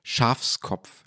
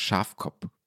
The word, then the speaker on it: Schafkopf: Schafskopf statt Schafkopf. Schafkopf gesagt. Das geht natürlich gar nicht. Also das S ist eine, äh, eine Sünde an diesem äh, bayerischen ähm, Volksheiligtum als Kartenspiel.